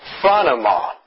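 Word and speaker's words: phronema (φρόνημα): And it is, the pronunciation of the word is phronema, which means nothing, I suppose, but that is how it is pronounced.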